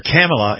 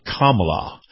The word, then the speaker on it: Kamala: He kept calling her Kamala instead of Kamala.